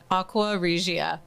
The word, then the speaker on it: Aqua Regia: Did I get it right? Regia? Yeah. Can you pronounce it better than that? – Aqua Regia.